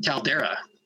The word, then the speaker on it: Caldeira: Caldeira. Caldeira. The E before the I is Portuguese, yeah.